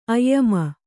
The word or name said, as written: ಆಯಾಮ